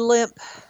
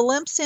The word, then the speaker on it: Lemp: It would be Lemp. Mansion in St. Louis. It's spelled L-E-M-P.